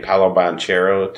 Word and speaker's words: Banchero: Paolo Banchero, did I pronounce that correctly? – Yeah.